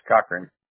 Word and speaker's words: Cochrane: It's Cochran. – How do you spell it? – C-O-C. H-R-A-N-E. – C-O-C-H-A-R-N-E. – No, C-O-C.